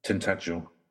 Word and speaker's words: Tintagel: Was it Tintagel? How do they pronounce that? – Tintagel. – Tintagel, yeah, thank you. I know I always pronounce it wrong, that's all I know.